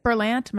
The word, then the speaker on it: Berlant: And then I saw that Kate, is it Berlant? Am I saying that right? – Yes.